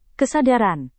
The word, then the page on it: kesadaran: indonesian